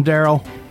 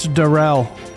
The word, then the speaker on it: Darell: I know I called him Darrell because his name's spelled like Darrell, but it's Darell.